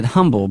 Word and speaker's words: Humble: There's no H in humble, right? – Well, there is an H, but it is silent.